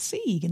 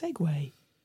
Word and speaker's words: segue: So I thought, is that Sieg? And then someone said, no, that's segue. Like, why is it pronounced like that when it's spelt Sieg? – Oh, my goodness me.